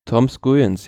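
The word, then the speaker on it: Skujiņš: Und am nächsten Tag, Entschuldigung, am nächsten Tag war es dann Etappe Nummer 3 natürlich, die gewonnen wurde von, wie spricht man denn aus? Skujens? – Tom Skujens, ja.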